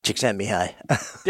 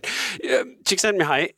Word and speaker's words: Csikszentmihalyi: Csikszentmihalyi. – There you go. Yeah. That wasn't actually that hard. Csikszentmihalyi, his description of flow, and not only the descriptions of flow, but its connection to happiness.